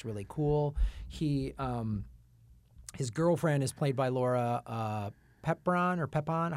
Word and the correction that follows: Prepon: How do I pronounce her? – Prepon.